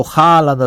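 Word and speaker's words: Haaland: Το Χάλαντ, ας πούμε, που λέμε εμείς, δεν είναι Χάλαντ. – Όλα καταραίουν. Εμείς το λέμε Χάλαντ και φροντίζουμε να ακουστούν και τα δύο Α.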